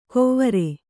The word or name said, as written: ಕೊವ್ವರೆ